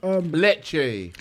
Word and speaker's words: Lecce: Oh, you said Nice, huh? – No, Lice. – Oh, my bad. – Lecce.